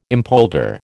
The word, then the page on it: impolder: American English